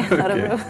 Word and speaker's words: Örebro: Did I spell it right? – Yeah, you did actually, Örebro.